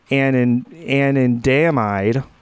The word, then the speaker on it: anandamide: And then this annotator says, marijuana causes the neurons in the brain to fire more rapidly, as THC resembles anandamide.